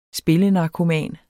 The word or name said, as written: spillenarkoman